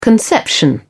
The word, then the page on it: conception: British English